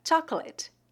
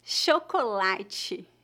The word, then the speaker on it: chocolate: Observe que o dígrafo CH é pronunciado exatamente como as letras SH, chocolate. Como a maioria das palavras em português, a sílaba tônica dessa palavra é a penúltima, chocolate.